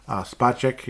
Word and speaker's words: Spacek: He's Space K, you know, like Space X, he's Space K, okay? That's how you pronounce it.